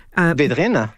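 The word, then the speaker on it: Védrines: Help me with the pronunciation, please. Benjamin Vedrin. – Vedrina. – Thank you.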